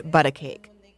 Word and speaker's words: Butta Cake: Mata is the founder, owner, and driving force behind Butta Cake, spelled like but a cake.